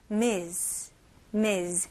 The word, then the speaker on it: Ms.: 스펠링은 M-S이지만 발음은 Z 발음에 더 가까워요. Ms. Ms. 네, 우리나라 말로는 뭐 누구누구님, 누구누구 씨에 가깝죠.